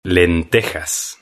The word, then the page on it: lentejas: Spain Spanish